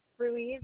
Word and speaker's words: Ruiz: Melissa's M-E-L-I-S-S-A. Ruiz is R-U-I-Z as in zebra.